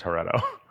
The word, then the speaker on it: Heretto: And then after a couple of days, I was like, I can just add a T to this and we can pronounce it the way that Casey wants to pronounce it, which is Heretto.